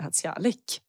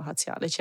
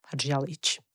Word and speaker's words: Hadžialić: Hadzialik. Eh, och det har jag hetat, trodde jag då, i Sverige. Eh, och sen insåg jag att herregud, det ska ju vara Hadzialic. Eller egentligen, om man verkligen ska uttala det på bosniska, ska det vara Hadzialic.